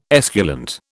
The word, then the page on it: esculent: British English